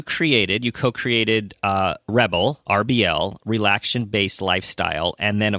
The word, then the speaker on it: RBL: So I have a company called Rebel. It's spelled RBL and it stands for relaxation based lifestyle.